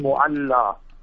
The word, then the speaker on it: Mu'alla: One is Mu'alla, the proper pronunciation, Mu'alla. Mu'alla means the upper region, and where the Zilkarn and Zamzam, that is Mispala, the lower region.